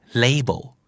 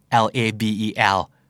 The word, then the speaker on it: label: ๆกันนะครับแล้วก็ออกเสียงผิดเพี้ยนกันบ่อยๆเช่นเดียวกันนั่นก็คือ label อ่านว่า label ใช่ไหมครับแล้วว่าป้ายส่วน rebel ก็อ่านว่า rebel ใช่ไหมครับหมายถึงกบฏเอาคำแรกก่อนป้ายเนี่ยจริงๆควรจะออกเสียงว่า label ไม่ลานะฮะ label l a b e l label